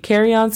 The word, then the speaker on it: carry-on: Muy bien, sí, carry-on significa equipaje de mano. La pronunciación fonética es K-E-A-R-I, carry, y después on es A-N.